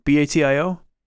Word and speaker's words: Batio: Michelangelo Baccio or that's right, right? Batio.